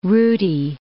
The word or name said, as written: rooty